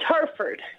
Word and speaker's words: Hereford: Did I pronounce that correctly? – It's Hereford.